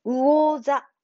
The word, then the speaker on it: 魚座: 魚座じゃなくて、この場合は魚。うお。発音は、うの口に、まあ、そのままですけれども、うおざ、うおざ、母音のうで、おですから、うお、うおざ、うおざ、うおざ、うおざです。